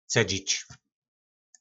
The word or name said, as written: cedzic